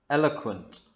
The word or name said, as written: Eloquent